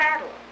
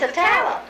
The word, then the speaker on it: Atala: It's not Attala. – No, it's Atala. Atala. – Oh, because it's Attala. There's an Attala County in Mississippi that's spelled a little differently.